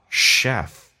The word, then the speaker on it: chef: natural, 记住这个单词没有 a, 很多人会发成 natural, no, natural, l, 舌尖抬起来一点点， natural, chef, 这个单词不要读成 chef, 哈，叫 chef, who is a natural chef, 天生的厨师。